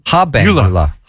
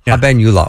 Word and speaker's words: habenula: And one of the things you talked about was the eubenula. Did yes. – I say that right? – Eubenula, yes. – Abenula? – Habenula. – Habenula.